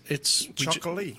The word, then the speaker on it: Txakoli: If you're out and you're out at a Spanish restaurant, ask for a Chocolí. – Gotcha. – If you don't know how to pronounce it, it's Chocolí.